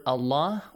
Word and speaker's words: Allah: So we said that if we have the word Allah or Allahumma, for the pronunciation of the letter Lam in this word, we have two cases, either lightly pronounced or it will be heavily pronounced. We just need to take a look at the harakat before, or the vowel sound before. If there is a kasra, the word Allah, the Lam, the letter Lam in the word Allah will be pronounced lightly.